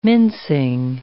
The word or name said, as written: mincing